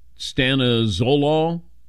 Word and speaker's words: stanozolol: Stanozolol, or Dylan, you got a better pronunciation on that? – That sounds about right.